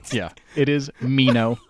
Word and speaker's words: Meno: So his name in Greek is actually Menon, like somewhere between A and E. It's M Eta Nu. Omega Nu, I think is how you spell it in Greek. So it's like it's pretty close. If you want to say Meno, we say it Meno in English.